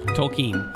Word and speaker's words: Tolkien: It's Tolkien, right? – Tolkien. – Tolkien? – Mm-hmm. – Man, why can't I pronounce anything? – Did we need a word for that? – I don't know. How to pronounce. Oh, it's her. – Tolkien. – You're right. – Tolkien. Yeah.